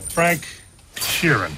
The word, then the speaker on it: Frank Sheeran: Frank Sheeran. Am I saying that right? – Yeah, you said it right.